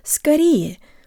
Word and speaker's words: скорее: Hey guys, today you'll learn how to pronounce the word скорее, which means sooner, or sometimes soon, as in the phrase поправляйся скорее, or get well soon. Tell your brain the O represents a schwa sound, and use good default Russian mouth position.